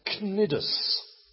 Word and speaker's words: Cnidus: They arrived with difficulty off this wonderfully spelt place, which is pronounced, for the next time we read this passage and I get a volunteer to read it, is pronounced Cnidus.